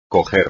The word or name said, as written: coger